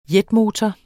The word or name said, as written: jetmotor